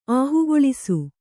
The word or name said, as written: ಆಹುಗೊಳಿಸು